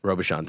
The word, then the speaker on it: Robuchon: Robuchon, thank you. – Yeah, sorry, I was forgetting. They're kind of a riff off Robuchon potatoes.